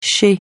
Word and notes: chez: francais

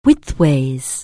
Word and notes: widthways: anglais

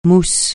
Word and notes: mousse: francais

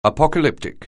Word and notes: apocalyptic: anglais

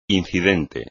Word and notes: incidente: espagnol

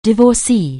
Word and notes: divorcée: anglais

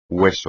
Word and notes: hueso: espagnol

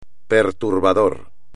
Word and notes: perturbador: espagnol

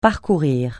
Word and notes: parcourir: francais

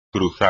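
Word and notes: cruzar: español

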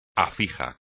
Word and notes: afijo: espagnol

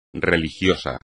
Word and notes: religioso: espagnol

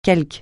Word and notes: quelconque: francais

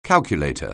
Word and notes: calculator: anglais

